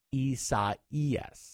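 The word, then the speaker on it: Isaías: Praying for the people who are in the path of Hurricane Isaias. – Yes, that's how you say it. – I'm trying to make sure I say it right. – I know. It's a hard one. – Time. It's Spanish for Isaiah.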